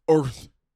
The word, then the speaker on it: Urth Caffé: Also, it's spelled U R T A. Yeah, it's Earth. Earth.